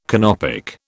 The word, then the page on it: Canopic: British English